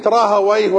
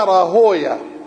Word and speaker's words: راهويه: هو إسحاق بن راهويه وكلمة راهويه وسيبويه ونفطويه تلفظ لفظين لفظ أهل اللغة هكذا سيبويه نفطويه مهرويه ولفظ أهل الحديث يقولون سيبويا نفطويا راهويا وكلاهما جائز والألفاظ الأعجمية يتوسع فيها نعم